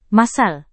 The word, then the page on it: massal: Indonesian